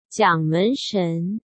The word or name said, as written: Jiang Menshen